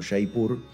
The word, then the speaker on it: Jaipur: Jaipur se escribe con J, pero se pronuncia como les estoy diciendo, Jaipur, es la ciudad rosa.